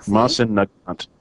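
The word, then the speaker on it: Mosin–Nagant: I don't know how that's pronounced either. Mosin Nagant. – Oh, yeah. Mosin. What did I say? Morin? Mosin.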